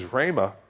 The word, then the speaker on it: rhema: The other word is rhema, R-H-E-M-A is how you'd spell it, which means specific statements of God.